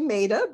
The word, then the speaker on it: Meta: Meta, please tell me I'm saying that correctly. Meta. – It's actually Meta, but that's Meta.